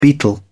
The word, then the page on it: beetle: British English